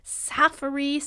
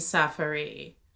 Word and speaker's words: Safaree: Ooh, I like the name Safari. – Yeah. – That's spelled S-A-F-A-R-E-E. – Yeah.